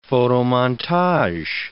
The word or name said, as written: photomontage